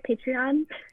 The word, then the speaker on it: Patreon: So, am I saying that right? Patreon? – Yep.